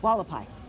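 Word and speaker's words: Hualapai: Is it Julia? I can't remember who they are. Hulapai? – Wallapai. – Wallapai, thank you.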